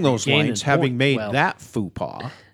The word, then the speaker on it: faux pas: It's faux pas.